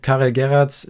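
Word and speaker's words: Karel Geraerts: Karel Gerrards, Schalker-Trainer, ist das richtig ausgesprochen? – Ist richtig ausgesprochen, der hat sich gar nicht mehr eingekriegt.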